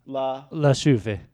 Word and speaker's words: La Chouffe: La Chouffe.